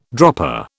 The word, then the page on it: dropper: British English